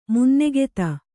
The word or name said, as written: ಮುನ್ನೆಗೆತ